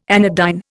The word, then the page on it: anodyne: Indian